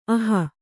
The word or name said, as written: ಅಹಿ